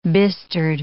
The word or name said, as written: bistered